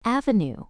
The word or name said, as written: avenue